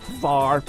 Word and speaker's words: Hvar: How do you pronounce that? H-V-A-R. Far.